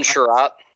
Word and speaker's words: Chiarot: Ben Chiarot.